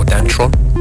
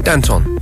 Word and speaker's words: Danton: Danton Ephraim, a very hard name to pronounce, Danton or Dantron. No, Danton, Danton Ephraim, with a track called Missing.